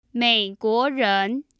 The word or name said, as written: meiguoren